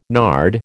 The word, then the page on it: nard: American English